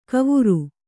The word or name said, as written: ಕವುರು